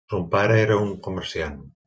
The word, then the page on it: comerciant: Catalan